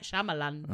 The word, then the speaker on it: Shyamalan: It's just M. Night Shyamalan. – Is it? – Yes. M. Night Shyamalan.